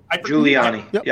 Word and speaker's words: Giuliani: Is that how we say his name? Giuliani? – Yeah. – Giuliani. Yep.